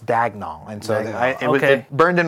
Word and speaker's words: Daigneault: Mark Daigneault, yeah. – Is that how you say it? – I think it's Daigneault. – I was like, Daigneault.